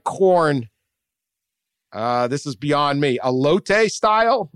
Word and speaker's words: elote: How did I say it? – Elote.